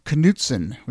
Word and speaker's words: Knutson: So it was a nice way to end 2020 and go into the new year with people telling me that it is actually pronounced Knutson, which is a very Minnesota way of pronouncing it.